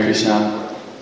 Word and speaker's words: Krishna: So similarly, we're chanting Hare Krishna mantra, and so we should chant Hare Krishna with the right pronunciation, as Prabhu was saying, particularly with the short A on the end of Krishna, not the long A, which is a long A with Krishna is indicating Draupadi.